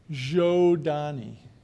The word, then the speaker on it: Zhodani: Zodani. Zodani. – Thank you. People on Twitter were asking. – Oh, okay. Well, yeah, write that down. Zhodani. Zodani. It's pronounced just like it's spelled.